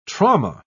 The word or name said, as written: trauma